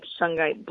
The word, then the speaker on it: shungite: So I also wanted to talk about shyite. Shyite is a stone from Russia. It's carbon-based. – You need to spell that. – S-H-U-N-G-I-T-E. – Shungite. There you go.